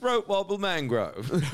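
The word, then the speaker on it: Throatwobbler Mangrove: No, it's spelled Raymond luxury yacht, but it's pronounced throat wobble mangrove.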